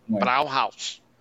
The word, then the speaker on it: Brauhaus: Brauhaus. ¿Cómo se dice, Brauhaus. – Ah, eso mismo.